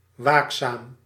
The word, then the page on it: aware: American English; [ə.ˈwɛə(r)]